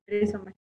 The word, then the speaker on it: Rizomar: ¿Rizomar se pronuncia? – Sí, Rizomar. Muy bien. – Grupo... Como el rizoma.